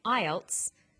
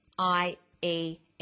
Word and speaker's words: IELTS: With an acronym, we don't pronounce the individual letters, we pronounce it as a word. So we say IELTS, not IELTS.